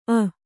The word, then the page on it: ಅ: Kannada